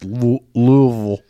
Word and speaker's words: Louisville: And that is pronounced Louisville. – Louisville. – That's Louisville.